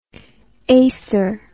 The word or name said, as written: acer